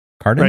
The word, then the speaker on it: Kardon: I looked at this video, the Harman, I always say the name wrong, Harman Kardon. Kardon? – Right.